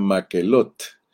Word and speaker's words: Macelot: Ok, entonces entendiendo esto, vamos a seguir un poquito más adelante porque hoy quiero terminar la lección de Macelot, que te dije que Macelot en hebreo se pronuncia Maquelot, así como se elata en español, en hebreo se pronuncia que, que elata.